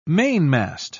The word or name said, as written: mainmast